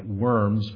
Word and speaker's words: Worms: He forced him by summons to a small village on the Rhine called Worms, Germany, spelled like worms, but pronounced in German with a V, Worms, Germany.